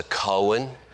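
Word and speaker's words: Cohen: I mean, that's C-O-H-E-N, that's how you spell it. No, it's coin, you know. So I've tried to learn to say coin instead of what it should be said, you know, coin.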